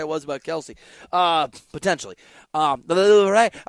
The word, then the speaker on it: Louvre: are just the things that hang in the Louvre. Is that how you say it? I don't even know. – Sure. – You see what I did there? – Yeah. – Louvre.